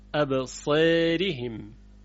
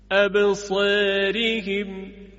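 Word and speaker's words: أبصارهم: دقت کنید، ابصارهم. چرا؟ چون را کسره داره و الف هم به خاطر کسره را اماله میشه، یعنی ظریف‌تر تلفظ میشه، ابصارهم.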